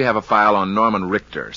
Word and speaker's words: Rickter: It's spelled R-I-C-K-T-E-R, Richter.